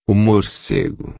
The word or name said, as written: morcego